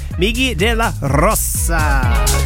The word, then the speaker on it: Miggy De La Rosa: Yeah, you got to roll your R's. Like this. Miggy De La Rosa.